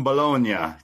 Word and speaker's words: Bologna: So this Friday, they're going to be in Italy, Bologna.